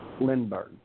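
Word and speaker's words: Lindberg: Chad, is it Lindenberg? Is that how you pronounce his last name? – Lindbergh. – Lindbergh, okay.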